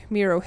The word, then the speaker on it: Miro: And then they were like, yeah, like the artist, Miro. I think they said that, so let's stick to Miro.